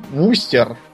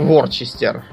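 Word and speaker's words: Worcester: Да, страшно сказать, есть еще такой Вустер, Угу. – Так вот он пишется как Ворчестер.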